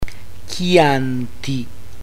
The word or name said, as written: chianti